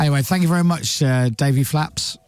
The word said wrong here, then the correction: Davey Flats: Davey Flats. Sorry, it's what? My mispronunciation. Flats. – Davey Flats.